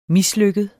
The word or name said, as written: mislykket